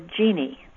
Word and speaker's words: Jeanne: My first name is spelled J-E-A-N-N-E. People ask if they should say Jean or Jeannie. Either is all right, but Jeannie is spelled with an I-E at the end. Jean is my given name, and Jeannie is my nickname. My last name is spelled capital D-U, capital P-R-A-U.